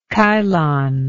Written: Chilon

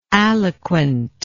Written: aliquant

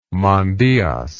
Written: mandyas

